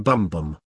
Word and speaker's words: bumbum: or unum bumbum. J. G. Bk, amongst whose writings we find this picaresque example of an alternative method of payment, clearly wishes us to pronounce the final word as rhyming with tumtum and hence invest it with a quite specific meaning, one up the Gary Glitter, as I believe the modern colloquialism to be.